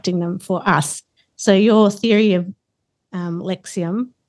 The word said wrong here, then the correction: Lexism: did I say that correctly then? – That was lexism.